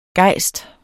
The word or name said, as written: gejst